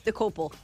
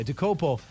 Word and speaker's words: Dokoupil: Dokoupil. Okay, Dokoupil as co-host of CBS this morning.